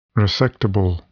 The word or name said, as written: resectable